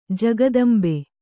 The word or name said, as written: ಜಗದಂಬೆ